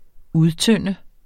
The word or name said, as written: udtynde